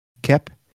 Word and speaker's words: Koepp: I don't know, K-O-E-P-P, Cope. How would you say that? – Kep.